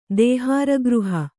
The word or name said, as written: ದೇಹಾರಗೃಹ